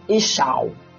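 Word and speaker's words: iysh-shaw: And the woman is Ishau. Ishau. Ishau. The same ish, I-Y-S-H. Then you bring dash. Then you, another S-H-A-W.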